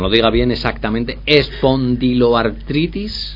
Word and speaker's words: espondiloartritis: a ver si lo digo bien, espondiloartritis. ¿Lo he dicho bien ahora? Espondiloartritis. Sí.